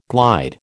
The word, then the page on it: glide: American English